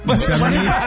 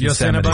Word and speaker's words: Yosemite: Yosemite? You didn't say it right, but we know what you meant. Yeah. Yes, Yosemite.